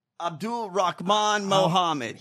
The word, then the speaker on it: Abdulrahman Muhammad: How do you say it? What is it? – Abdulrahman Muhammad. – Abdulrahman Muhammad. – He says it really fast.